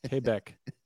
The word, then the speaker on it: Quebec: Alex Trebek always pronounced it to Quebec. Quebec. What is it? – I've heard Americans say Quebec before. No, it's Quebec. – Quebec.